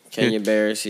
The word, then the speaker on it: Kenya Barris: Kenya Barris. Yeah. Did I say it right? Barris? – Yeah. Kenya Barris.